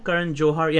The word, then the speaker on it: Johar: Karan Johar. Yeah, it's a hard H. – Karan Johar. Okay. – Yeah. – Karan Johar. – Yeah, you got to pronounce the H.